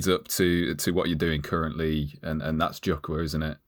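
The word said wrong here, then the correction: Jukwaa: Is that pronounced right? – Nearly right. And it's quite often pronounced like that, but it's really Jukwa, and it means platform in Swahili.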